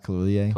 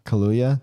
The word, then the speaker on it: Kaluuya: Is it pronounced Kaluuya? – Kaluuya. – Kaluuya? – Kaluuya. – Kaluuya. – K-L-U-U-Y-A. – Kaluuya.